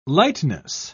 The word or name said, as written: lightness